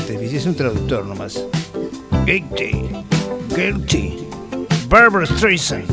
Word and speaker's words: guilty: Se escribe G-U-I-L-T-Y. Yo sé que el final sería Chai, pero busqué ahora y no exactamente. A ver cómo se pronuncia Gitchi. Que ya voy a buscar el significado. A ver, lo tengo acá. – Guilty. Guilty.